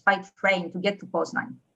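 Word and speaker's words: Poznań: Is it Poznan or Poznan where you went to school? How do you pronounce it? I've been there. I love it. How do you pronounce it? – Poznan. – Poznan.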